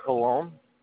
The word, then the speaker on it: Pallone: The last name is Pallone. – How do you spell it? – P-A-L-L-O-N-E.